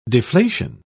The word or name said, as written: deflation